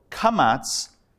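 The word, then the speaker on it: kamatz (קמץ): So kamatz either has an aw sound or a bit of an ah sound. We're going to go with a slight ah sound, like the tongue depressor when the doctor says, say, ah.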